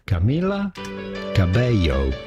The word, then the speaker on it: Camila Cabello: Camila Cabello.